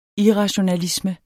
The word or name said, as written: irrationalisme